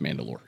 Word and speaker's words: Mandalore: The leader of the Mandalorians was known as the Mandalore, translating to soul ruler, and was rendered as, it's all spelling, doesn't matter, it's all pronounced Mandalore.